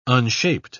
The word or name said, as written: unshaped